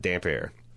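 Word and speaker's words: Damphair: But to me, it just read like this guy has got damp hair. He's this saltwater priest, and he's just always in the surf drowning people. So he's got damp hair. But that's how you pronounce it, damp hair.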